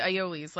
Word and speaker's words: aioli: He spoke about emulsions and he did aiolis, like different mayonnaise. – For those people who don't know that the word a I o l I is pronounced aioli.